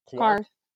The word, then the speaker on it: Qarth: Qarth.